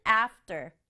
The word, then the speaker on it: after: 첫 번째 단어는 after. After. 발음이 after이지만 소리가 약간 된소리처럼 나요. 그래서 after.